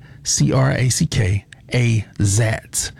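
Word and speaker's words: Crackazat: And here's a song that I've been loving for the past couple weeks, Crack-A-Zat. I always say it wrong. It's Crack, C-R-A-C-K, A-Zat. A-Z-A-T. That's C-R-A-C-K-A-Z-A-T. I think that's Crack. Yeah, Crack-A-Zat.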